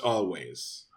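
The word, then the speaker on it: Alvvays: They're called Always, but the W in Always is two V's. but it is pronounced Always.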